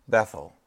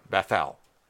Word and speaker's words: Bethel: So Jacob named the place where God had spoken to him Bethel, or Bethel. It can really be pronounced either way.